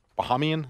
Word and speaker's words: Bahamian: A couple of bah- Bahamian, is that how you say it? Bohemian, yeah, Bahamian Islands, Yes.